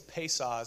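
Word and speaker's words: Pesach: But the actual Hebrew word, I think it's Pesah is how you say it.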